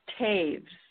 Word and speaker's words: Toews: It's actually pronounced Taves.